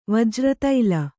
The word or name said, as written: ವಜ್ರತೈಲ